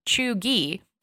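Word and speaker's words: chuggy: Chugi, pronounced Chugi, can be used broadly to describe someone who is out of date or trying too hard.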